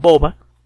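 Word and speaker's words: Bova: Boba. Eh, Boba se escribe con la primera B eh, labial y la segunda B labiodental.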